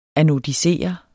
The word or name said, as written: anodisere